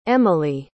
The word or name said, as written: emely